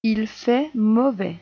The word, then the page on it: il fait mauvais: French